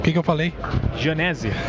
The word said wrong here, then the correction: Genesini: não, Janesini.